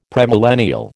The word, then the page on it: premillennial: American English